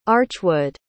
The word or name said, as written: archwood